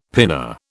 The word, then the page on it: pinna: British English